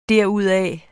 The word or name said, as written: derudaf